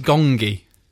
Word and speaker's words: Gonggi: it's spelt Gongi. – Ah. – G-O-N-G-G-I.